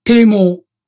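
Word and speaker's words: 剃毛: 堤毛。わかる？剃る毛と書いて堤毛。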